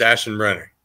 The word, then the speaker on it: Aschenbrenner: Aschenbrenner. Usually I tell people to pronounce it before I say it, but it's Aschenbrenner.